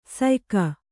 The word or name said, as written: ಸಯ್ಯುಗ